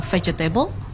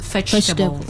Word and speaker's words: vegetable: Because sometimes you cannot just pronounce it like what it read, you know, like I think my students, a lot of them always said vegetable. But actually you should pronounce it vegetable. Some words are missing or sometimes a little weak like that, so you cannot pronounce all of the syllables.